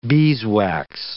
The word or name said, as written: beeswax